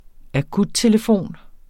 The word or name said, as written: akuttelefon